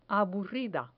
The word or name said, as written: aburrida